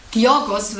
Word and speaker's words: Georgios: Ich weiß nur, Georgos wird es ausgesprochen, der Vorname, weil er Grieche ist.